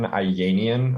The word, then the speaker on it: Ayanian: Uh, you can check me out on Twitter at Benjamin Ayanian.